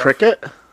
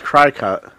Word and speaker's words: Cricut: Wait, this it's pronounced stuff. Cricket? – Yeah. I know, it's spelled like I thought cry, it was Cry-Cut. – Cry-Cut? No, it's Cricket.